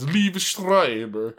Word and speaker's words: Liev Schreiber: But he's voiced by Liev Schreiber. That's how you say his name. I'm not sure what that was. – It's Liev Schreiber.